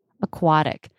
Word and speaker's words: aquatic: Aquatic. – No, it sounds like a D. – Yeah, it's a flap T.